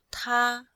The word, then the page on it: ta1: female